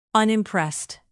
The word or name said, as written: unimpressed